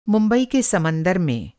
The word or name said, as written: समंदर